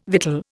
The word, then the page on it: victual: Indian